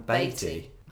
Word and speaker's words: Beatty: Well, I heard he added the T because he wanted people to pronounce it correctly, so it rhymed with weighty, not Wheaties. – Oh, so it's what? So his name is Warren Beatty.